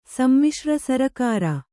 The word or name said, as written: ಸಮ್ಮಿಶ್ರ ಸರಕಾರ